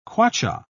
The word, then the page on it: kwacha: British English